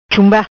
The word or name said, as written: Chumba